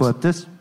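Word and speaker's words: Annuit coeptis: I always mispronounce this. – Anoequeptus. – Anoequeptus. Thank you.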